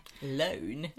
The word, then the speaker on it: Lone: Lone. Jag vet inte hur det stavas. Men... – L-O-N-E.